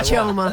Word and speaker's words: Chelmer: I've never heard Shelmer in my Chelmer.